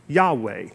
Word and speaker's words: Yahweh: This is actually the name of God, a word that best we understand is pronounced Yahweh.